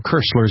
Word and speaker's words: Koestler: He kind of played down the fact that later in his life, Arthur Kessler, or Kessler as it would have been pronounced in the German, he was actively involved in parapsychological research.